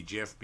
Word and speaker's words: GIF: Well, there's also a brand of peanut butter out there, GIF peanut butter, that's spelled J-I-F. – Yeah, with a J. – So I'm automatically, if I see G-I-F, I'm actually going to think the hard G,